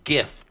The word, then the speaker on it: GIF: But the whole thing is that the GIF, it's a hard G, like mm-hmm. Google, mm-hmm. like gonad, mm-hmm. – like grab it.